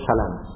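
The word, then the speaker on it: سلام: قال حدثنا أبو عبد الرحمن العتكي قال حدثنا محمد بن قال حدثنا محمد انت نلبيكان ديا berarti bacanya salam Muhammad bin Salam, fathanya diringankan, tidak ditasydid, tidak dibaca salam.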